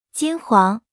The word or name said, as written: 金黄